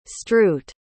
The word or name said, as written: stroot